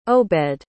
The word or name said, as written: Obed